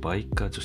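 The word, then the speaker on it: 梅花: 概要欄にリンクを貼っておくので後でぜひ見ていただきたいんですけども、記事の内容をですねざっくりお伝えしますと、池永鉄工という会社とバイカ女子大学、梅の花って書いてバイカと読むらしいんですけど、そちらの女子大学とのコラボレーションでマカロンの形をした鉄玉を商品化しました。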